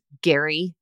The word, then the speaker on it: Gerry: I'm like, or his name is spelled G-E-R-R-Y. So it's Gary.